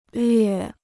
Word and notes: 略: llüè

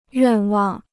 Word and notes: 愿望: yuàn wàng

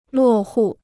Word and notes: 落户: luò hù

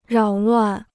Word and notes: 扰乱: rǎo luàn

